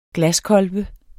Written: glaskolbe

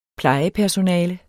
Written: plejepersonale

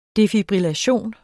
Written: defibrillation

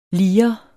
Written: lirens

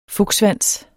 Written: FUKSSVANSEN

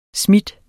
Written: smide

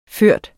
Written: førende

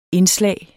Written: indslag

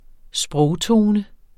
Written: SPROGTONE

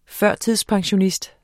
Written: pensionskunde

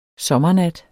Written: sommernat

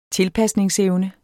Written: tilpasningsevne

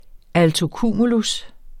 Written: altocumulus

